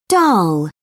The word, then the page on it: dhal: British English